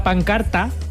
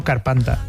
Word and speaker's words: pancarta: sacaron una pancarta. Mira, lo he dicho bien, siempre digo carpanta.